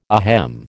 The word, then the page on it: ahem: American English